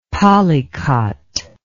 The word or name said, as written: polycot